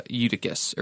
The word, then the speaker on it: Eutychus: Paul raises Eutychus. Is that how you pronounce it? – Yeah.